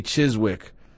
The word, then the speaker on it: Chiswick: So, Van Winkle says the W is silent, but after all these years of pronouncing the W, I can't change. So I'm going to say Chiswick. Again, right or wrong, I respond, who cares?